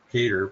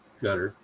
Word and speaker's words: Qatar: I know it looks like it's pronounced Qatar, but it's Qatar, according to the public documents.